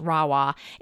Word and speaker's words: Rahua: So it's spelled R-A-H-U-A. It's pronounced Rawa.